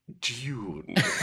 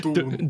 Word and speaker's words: Dune: the I pronounce the movie we're gonna muff D O O N. Dune. Dune. Dune.